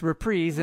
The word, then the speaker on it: reprise: It's reprise, and that's the right way to say it.